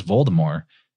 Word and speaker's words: Voldemort: But that Voldemort, and actually in the audiobook, the reader doesn't even say the T, it's a silent T. So he says Voldemort.